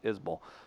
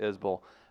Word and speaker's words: Isbell: I feel weird when you say that, too, because Isbell sounds better, but yeah, it is Isbell. So there it is. Guys, it's Isbell? I've been saying Isbell. – It's everyone Isbell. Does it, but he pronounces it Isbell.